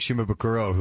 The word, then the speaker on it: Shimabukuro: If you're just tuning in, it's Jake Shimabukuro, who's on the show. I said it right that time, didn't I? – Oh, that was perfect.